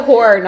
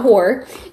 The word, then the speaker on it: horror: It's a it's a horror novel. – It's a basic book. – It's a horror. Let me emphasize that that is two syllables. Horror, not whore.